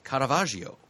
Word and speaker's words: Caravaggio: And on the next page, page 62, we've got an autobiographical hardcover about the great Renaissance artist Caravaggio. – I was waiting for you to pronounce that with bated breath. Magnificent.